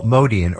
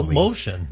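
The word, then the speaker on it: Motian: It's Paul Motion. – It's Motion. It's Motion. Okay, like physics.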